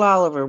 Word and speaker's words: Olivier: Is it Olivier or Oliver? We've had this discussion. – It's Oliver. It looks like it's spelled Olivier, but it goes by Oliver.